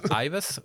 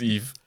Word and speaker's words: Yves: Das heißt Yves. – Yves. – Yves. – Oder Ives. Oder Yves. Nee, Yves, es ist Yves. – Yves, ne? Ja, Yves